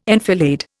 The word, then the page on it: enfilade: Indian